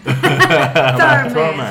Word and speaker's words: Thurman: Yo aprendí a pronunciar su apellido en un curso con Marta de baile. Thurman.